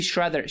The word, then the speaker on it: Schrader: ¿Schrader? ¿María Schrader, María Schrader. Eh, sí, Shredder. She, she, Shredder. Shredder, como el Shredder, como el de las tortugas ninja.